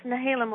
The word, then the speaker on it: Nehalem: Nehalem, Oregon. Am I saying that right? – Yes, Nehalem, Oregon.